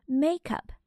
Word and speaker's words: makeup: Makeup 是一个名词，它的重音呢在第一个音节上。